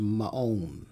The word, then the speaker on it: Ma'on: In the Bible, in the Hebrew, it's pronounced Ma'on.